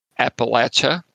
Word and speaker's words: Appalachia: The first thing you need to know is how to pronounce it, Appalachia, or like throwing an apple at you.